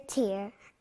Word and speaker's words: tear: Ear, ear, tear. Ear, ear, tear. Ear, ear, tear. – Excellent. Ear 소리가 나는 그 다음 단어는요.